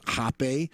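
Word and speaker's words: rapé: Now, one is called Rape, and spelled R-A-P-E with an asterisk over the E. Some people pronounce it Hape,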